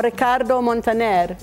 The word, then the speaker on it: Ricardo Montaner: Guaco or Ricardo Montaner? – Oh, okay.